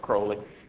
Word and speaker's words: Crowley: but he called himself Aleister Crowley to rhyme with holy, but he was the opposite of everything that's holy.